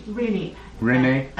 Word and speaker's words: Rini: Rini. Americans mispronounce my name. – What is it, Rini? – Rini, yes. I dropped the I off.